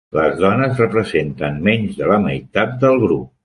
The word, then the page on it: representen: Catalan